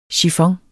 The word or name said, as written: chiffon